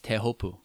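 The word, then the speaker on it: Tehopu (Teahupoʻo): Is that how you pronounce it? – I'm trying not to be just the full on like lame tourist Teopu. You know, it's like. It's I think it's Tehopu. – Tehopu. That's how they call it.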